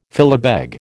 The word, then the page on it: filibeg: American English